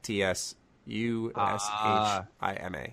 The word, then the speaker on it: Tsushima: It's, there's a T in the beginning, a silent T. T S U S H I M A.